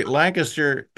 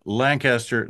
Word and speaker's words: Lancaster: Do you hear the difference? You'd say Lancaster, Pennsylvania, one syllable, Lancaster, Nebraska, two syllables.